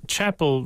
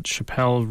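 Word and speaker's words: Chappell: It's Chapel Roan, not Chappelle Roan,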